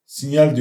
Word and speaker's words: Signal: Öbür taraftan Telegram ve e, Signal, Sinyal diye okunuyor. Signal diye yazılıyor. Signal.